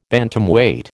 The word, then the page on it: Bantamweight: American English